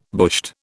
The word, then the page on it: bushed: British English